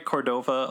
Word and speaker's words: Cordova: Cordova, Alaska? – You are saying it correctly.